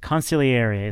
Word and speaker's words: consigliere: I want to say consigliere, but consigliere is the right way to pronounce it, I guess. – Sure. As someone who's definitely fluent in Italian, I can tell you that is, you got it perfect.